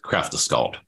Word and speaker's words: Kraftaskald: It's Kraftaskald. Am I pronouncing that right? – Yes, Kraftaskald.